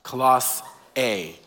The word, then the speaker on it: Colossae: How do you pronounce the name of this city? And if you're like me, I read it on paper and I'm like, how do I say that word? We seem to have a hard time with it. So to make it easy, think like a Canadian. Colossae.